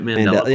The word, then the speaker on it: Mandela: It's Mandela, isn't it? Mandela. – Yeah, that's it.